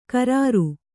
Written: ಕ್ರೂರ

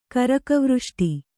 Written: ಕರಕವೃಷ್ಟಿ

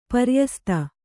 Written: ಪ್ರಯಸ್ತ

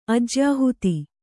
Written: ಅಜ್ಯಾಹುತಿ

